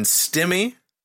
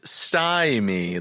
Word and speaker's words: stymie: And stimmy, that's actually pronounced stymie. The clue there is the Y in it, as I'm to understand.